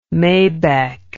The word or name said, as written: maybeck